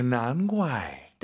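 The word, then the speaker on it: 难怪: 자, 발음하실 때 선생님 이 난怪 우리가 이제 GUAI 사성이니까 怪 쌍격으로 많이 발음해요. 난怪가 예뻐요. 난怪가 예뻐요. – 다 예뻐요. – 다 예뻐요. 근데 쌍기역으로 난과이 이렇게 발음하는 중국인도 있죠. 근데 우리 선생님은 난과이 기역 느낌으로 발음을 했다는 걸 참고하시면 좋을 것 같습니다.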